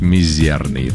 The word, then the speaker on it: мизерный: А, однако вариант с ударением на второй слог считается классическим и строго литературным, но он есть. То бишь, «мизерный», он допустим в нашей речи, но если вы в какой-то момент решили блеснуть безупречной грамотностью, выбирайте вариант «мизерный», либо «мизерный».